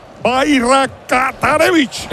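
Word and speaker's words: Bajraktarević: Bayrakatarevich. – Le podemos llamar Esmir, que es el nombre. Puedes decir Barata y luego pones Revich.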